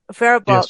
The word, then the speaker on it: Faribault: Faribault? Am I getting the name right? – That's correct. – Faribault. Yes.